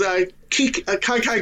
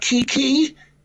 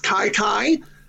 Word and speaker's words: Kyler: And to know how to pronounce his first name, because it's not Kiki, it's Kai Kai.